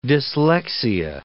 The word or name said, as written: dyslexia